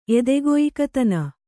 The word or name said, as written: ಎದೆಗೊಯ್ಕತನ